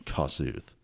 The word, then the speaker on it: Kossuth: But I've been told it's Kossuth, not Kossuth. So hard on the first syllable there, Kossuth.